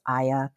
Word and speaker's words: Aya: My name is Aya Kanai.